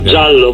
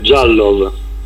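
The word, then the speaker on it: Diallo: Dunque, insomma, ottima impressione suscitata dalla regina, dal mister Marco Marchionni in questa prima uscita nella quale non abbiamo visto Sulaiman, ora ci dici tu come si pronuncia, perché io ieri parlando con Stellone mi diceva Iallo, però Stellone non sa neanche l'italiano, ecco, figuriamoci. Giallo ve la pronuncia.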